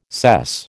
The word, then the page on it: sess: American English